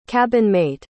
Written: cabinmate

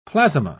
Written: plasma

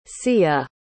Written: seia

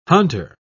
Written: hunter

